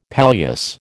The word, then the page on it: palliasse: American English